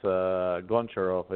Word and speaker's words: Goncharov: Yeah, my last name is a little bit hard to pronounce. It's Goncharov. It's just Slavic last name.